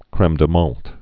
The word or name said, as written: creme de menthe